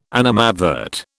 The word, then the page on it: animadvert: British English